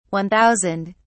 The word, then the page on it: one thousand: British English